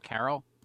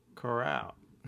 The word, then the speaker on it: Corral: Isn't that Carol? – Corral. Golden Carol. – Golden Carol. That's Golden Coral. Golden Corral.